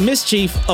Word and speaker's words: MSCHF: It's spelled MSCHF, which is basically Mischief, of course, which is a play on a word, or shall I say, removing the vowel out of the word mischief, right?